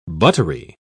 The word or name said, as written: buttery